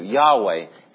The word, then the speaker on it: Yahweh: The interesting thing about Yahweh's name is we don't even know if we're saying it right. Our God is so awesome, we don't even know if we're saying His covenant-keeping name right.